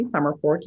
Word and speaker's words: Somerford: My name is Tracy Summerfork.